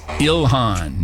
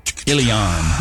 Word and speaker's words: Ilhan: Austin, mispronouncing Ilhan, adding an extra little country Y. It's Ilhan, not Ilhan. Han.